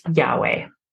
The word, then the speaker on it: Yahweh: And we've talked about this before, but through the eons, the name of God has been pronounced Yahweh. And what that means is that's that universal sound that we all have within us. It's that breath, the Yah and the way.